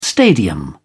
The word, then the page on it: stadium: British English